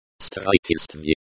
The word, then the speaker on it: строительство: Строительство. Строительство. Means construction. It is the prepositional case form of the noun construction. Строительство. Строительство. The accent is on the third syllable. Pronounced строительство, stv, like sent in street.